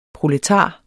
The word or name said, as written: proletar